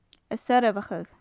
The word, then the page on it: a soirbheachadh: gaelic